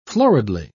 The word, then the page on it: floridly: British English